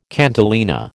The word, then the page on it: cantilena: American English